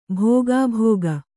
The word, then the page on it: ಭೋಗಾಭೋಗ: bhōgābhōga